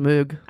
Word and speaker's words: Moog: It's W-O-O-B-L-E-S. – Maybe they are. – But anyway, I wanted... – M-O-O-G is pronounced Moog.